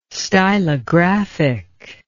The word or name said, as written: stylographic